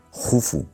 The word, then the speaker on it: Jufu: se escribe Keops, pero se pronuncia Jufu, y casi nadie le llama Jufu, porque Herodoto nos lo tradujo del griego y bueno, pues ahí se ha quedado, ¿no?